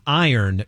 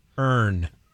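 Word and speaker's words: Irn: It's pronounced iron, not urn.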